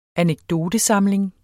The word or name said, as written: anekdotesamling